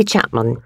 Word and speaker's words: Chapman: Yes, it's Ellie Chapman.